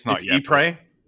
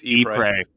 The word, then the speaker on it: Ypres: I looked this up when we did it before, but I'm not going to remember how to pronounce it. – Yeah. – Woods of Ypres. – It's Ypres. It's Ypres.